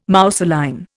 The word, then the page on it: mousseline: Indian